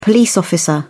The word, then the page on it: police officer: British English